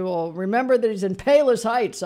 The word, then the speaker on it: Palos Heights: Palos Heights. Did I say that right? – Palos Heights, man.